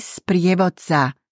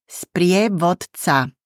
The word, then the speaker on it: sprievodca: For all males, it is turistický sprievodca, meaning tourist guide. Repeat, sprievodca. One more time, sprievodca.